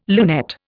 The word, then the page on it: lunette: Indian